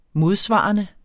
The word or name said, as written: modsvarende